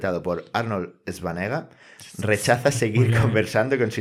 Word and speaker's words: Schwarzenegger: Bueno, pues el tema es que se pronuncia así, Schwarzenegger.